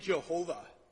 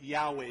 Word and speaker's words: Yahweh: So I grew up with hearing Jehovah. And somewhere along the line, the scholars are like, no, that doesn't really seem right. And so they started pronouncing it Yahweh. It's the same vowels, the same consonants with just different vowel soundings.